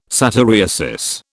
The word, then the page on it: satyriasis: British English